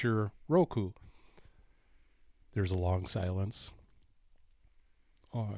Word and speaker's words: Roku: Is that how you pronounce Roku.